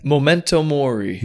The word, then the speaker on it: Memento mori: Memento mori.